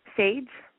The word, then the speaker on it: Sage: Sage. – How do you spell that? – S-A-G-E. – S-H-E? – S-A-G-E. G-E. – Oh, S-A-D-E, Sade? – G, like Gary.